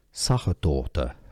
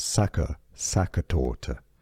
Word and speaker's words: Sachertorte: The German pronunciation, Sachertorte. Okay. Yes, I know. In English, it's not generally pronounced this way. It's often pronounced as Sachertorte.